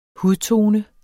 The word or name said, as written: hudtone